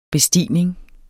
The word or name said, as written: bestigning